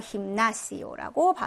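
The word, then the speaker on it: gimnasio: 맨 끝에 이중 모음 와 있습니다. 그죠? 그래서 끝에서 두 번째 음절 모음에 강세가 와서 힘나시오라고 발음해 주시면 되고.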